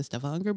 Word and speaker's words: Stavanger: And so I was in Stavanger, where I played for the Stavanger Blues Club, then Sadness, and then... Back at I'm impressed by your Norwegian pronunciation. – It's the singer's ear. – Stavanger and Sandnes.